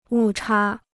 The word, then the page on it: 误差: wù chā